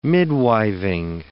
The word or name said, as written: midwiving